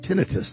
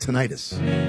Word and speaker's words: tinnitus: This is about tinnitus. That's how it's pronounced, Tom. Not tinnitus. – Oh. – It's actually, they're both correct.